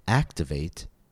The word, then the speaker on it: activate: Notice how these words all end with the suffix A-T-E, and yet the stress falls on a different syllable of each word. For example, in activate, it falls on the first syllable, activate. In investigate, it falls on the second syllable, investigate.